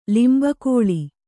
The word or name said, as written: ಲಿಂಬಕೋಳಿ